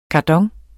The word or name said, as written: kardon